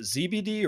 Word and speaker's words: ZBD: Is it ZBD or Zebedee? – Yes, in America it would be ZBD, but in England it would be Zebedee.